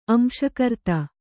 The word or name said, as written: ಅಂಶಕರ್ತ